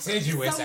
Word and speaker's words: contiguous: Like contiguous, actually, is how you say it. – Over it's not continental, it's contiguous. Because it's connected.